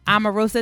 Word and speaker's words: Omarosa: So everybody knows that Omarosa, is that how you say it? – Yeah.